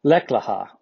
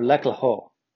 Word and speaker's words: Lech Lecha: The phrase is Lech Lecha or Lech Leho. It's spelled L-E-C-H and then L-E-C-H-A, Lech Lecha, Lech Leho.